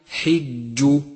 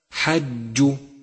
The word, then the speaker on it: حج: سادسا اقرأ كلمة حج، حج، اقرأها بشكل صحيح وانتبه لكسرة الحاء، فهي حج، والخطأ الشائع حج، فانتبه وتدبر.